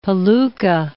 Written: palooka

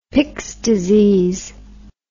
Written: Pick's disease

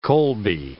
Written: Colby

